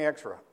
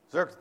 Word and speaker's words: Xerxes: This is what happened during the time of King Xerxes. I said it wrong. I can't say it right now. Somebody say it right. Xerxes, thank you.